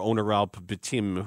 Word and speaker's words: Onuralp Bitim: Before we hear from the man of the hour, Onuralp Bitim, who made his debut. – By the way, I got confirmation that you are saying that correctly.